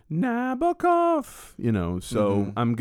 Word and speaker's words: Nabokov: But are you talking about Nabokov? – No, I know Nabokov. – Well, it's the emphasis is supposed to be on the B-O.